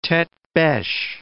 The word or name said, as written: tete-beche